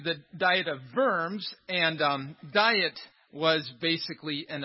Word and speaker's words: Worms: And that's how we pronounce it in English. Don't get confused. Some people think, wow, what were they making Luther eat? It's actually the German pronunciation is Diet of Worms.